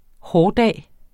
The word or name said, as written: hårdag